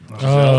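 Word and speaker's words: Gisele: Is that how you say her name? – Giselle. Oh, yeah.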